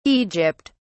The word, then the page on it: Egypt: British English